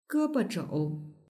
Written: gebozhou

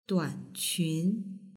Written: duanqun

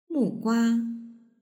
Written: mugua

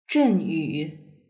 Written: zhenyu

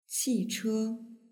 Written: qiche

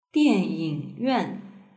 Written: dianyingyuan

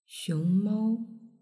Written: xiongmao

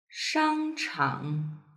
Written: shangchang

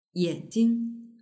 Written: yanjing

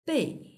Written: bei

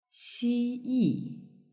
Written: xiyi

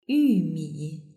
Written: yumi

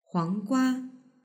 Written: huanggua

